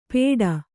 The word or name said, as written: ಪೇಟ